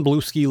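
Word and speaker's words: Bluesky: I yeah I think I've been on Blue Ski long enough. I refuse to pronounce it the other way. It's Blue mm. Ski. – Mm. – Blue Ski. Um, yes.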